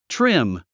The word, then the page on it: trim: trím; トリム